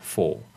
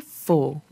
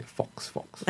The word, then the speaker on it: Faux: I think first when I came across the word, right, I didn't know how to pronounce it properly. I was just calling it Fox.